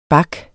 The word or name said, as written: bak